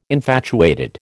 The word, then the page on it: infatuated: American English